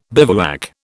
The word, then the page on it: bivouac: British English